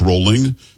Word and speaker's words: Rowling: Having said that, a lot of people think it's J.K. Rowling. It's not. It's Rowling because she says Rowling, but I'm with you.